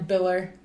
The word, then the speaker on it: Biller: And then Biller, like Miller, but with a B instead.